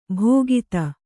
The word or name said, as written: ಭೋಗಿತ